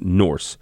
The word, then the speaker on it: Norxe: It's spelled N-O-R-X-E, but you pronounce it Norse.